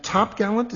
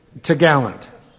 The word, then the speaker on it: topgallant: Course, topsail, and topgallant? Is that how you pronounce it? – Yeah. – T'gallant. – What? Spell that. – T-O-P-G-A-L-L-A-N-T.